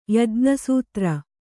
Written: ಯಜ್ಞಸೂತ್ರ

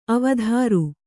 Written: ಅವಧಾರು

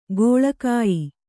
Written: ಕೀಳುಗಯ್ಯು

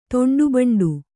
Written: ತೊಂಡುಬಂಡು

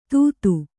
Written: ತುತ್ತು

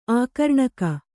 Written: ಆಕರ್ಣಕ

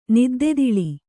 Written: ನಿದ್ದೆತಿಳಿ

